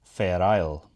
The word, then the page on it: Fair Isle: Shaetlan